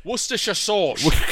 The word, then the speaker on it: Worcestershire: Worcestershire sauce.